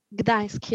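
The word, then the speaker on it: Gdańsk: No, I just went to Warsaw and I went to, I'm not sure if I'm pronouncing this correctly, but Gdansk. – Gdansk, yeah.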